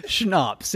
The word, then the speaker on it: schnapps: I go to pay, and I'm like, holy snaps, I don't have my bank card on. – Snaps doesn't start with a sh. – Schnapps.